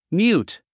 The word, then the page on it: mute: mjúːt; ミュート